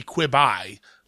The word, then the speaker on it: Quibi: I've been pronouncing it Quibi this whole time. I know it stands for Quick Bites, but I guess that it's supposed to be Quibi. Like ribeye, which intrigues me because I love ribeye steaks.